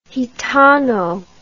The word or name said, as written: gitano